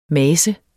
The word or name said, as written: mase